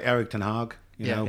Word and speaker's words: Ten Hag: Erik Ten Hag.